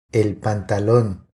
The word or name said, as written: El pantalon